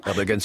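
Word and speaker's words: Erdoğan: Erdogan c'est bon.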